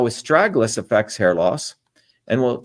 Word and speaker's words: astragalus: So again, as I started off, astragalus, that's the way you pronounce this, is known as a guardian root.